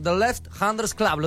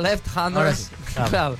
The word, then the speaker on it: The Left Handers Club: The Left Handers Club, ¿lo dije bien, Alejo? – Muy bien. – Left, ¿y qué es eso? – El club de los zurdos. Yo le explico a los que no saben idiomas como yo. A ver, decilo vos, Alejo. – Ah, Left Handers. Sí, lo pronunciaste perfecto.